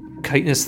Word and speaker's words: chitinous: Did you say chitinous? – Sure did, baby.